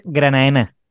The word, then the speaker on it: Granadina: Eh, con nosotros se puso en contacto Sergio Alonso, que es de la Sociedad Astronómica Granadina, aunque se, escribe Granadina, pero todos sabemos que se pronuncia Granaína, que mm, está, están organizando... – En realidad se pronuncia Granaena.